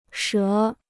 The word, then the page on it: 折: shé